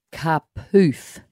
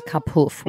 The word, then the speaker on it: Kapoof: And just a tip, it's Kapoof, not Kapoof, when you get to that bit. – Yes. – I have to remember that. – Yeah, it's got to rhyme with roof.